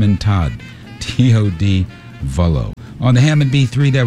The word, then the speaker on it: Vullo: Music from Tom Vola. Is that right? Did I say that right? I think I did. Todd Vullo, V-U-L-L-O.